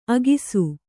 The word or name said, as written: ಅಗಸೆ